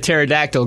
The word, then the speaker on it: pterodactyl: Why can't you hear a pterodactyl go to the bathroom? – Why can't you hear a pterodactyl go to the bathroom? – Because the P is silent.